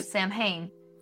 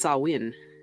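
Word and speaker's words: Samhain: Because I've been saying Sam Hain. – No. Yeah, that's how I used to say it. It's not. It's Sáwin.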